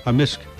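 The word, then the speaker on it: amisk: A misk. – A misk. So the K can be silent, but in generally it's...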